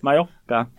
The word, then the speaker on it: Mallorca: Du, eh, jeg har vært i, på Mallorca, som jo uttales med en J istedenfor to L-er, fordi at spanjoler ikke kan verken stave eller uttale ting.